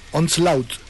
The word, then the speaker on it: Onslaught: Onslaught.